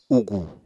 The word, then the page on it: ugù: Apatani